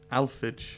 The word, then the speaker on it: Alphege: Alphage. – Alphage. – A-L-P-H-E-G-E.